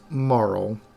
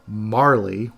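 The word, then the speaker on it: Marle: So he meets Marl, or as I incorrectly pronounced in my head since the first time I played it, Marley, which is not right.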